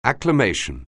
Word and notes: acclamation: anglais

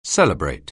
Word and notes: celebrate: anglais

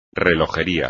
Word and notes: relojería: espagnol

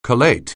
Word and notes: collate: anglais

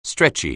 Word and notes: stretchy: anglais

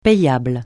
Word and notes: payable: francais

